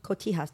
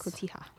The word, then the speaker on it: Cotija: Cotija cheese? – Cotija cheese. – Cotija.